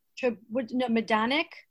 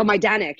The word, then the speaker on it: Majdanek: Majdanek. – Oh, Majdanek. Yeah. I saw that one. – Majdanek, yeah.